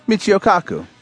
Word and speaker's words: Michio Kaku: Michio Kaku.